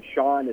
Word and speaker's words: Sean: Yeah, so my name, Sean, is the S-E-A-N version, and then my last name, Hussey, H-U-S-S-E-Y.